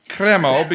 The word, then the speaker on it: Cremo: Cremo, because I'm sophisticated.